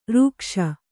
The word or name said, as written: ಋಕ್ಷ